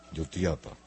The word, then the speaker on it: Jutiapa: Es así como se pronuncia, ¿verdad? Jutiapa, para que... Jutiapa. – Sí.